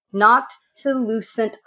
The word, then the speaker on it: Noctilucent: Noctilucent clouds.